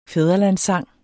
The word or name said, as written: fædrelandssang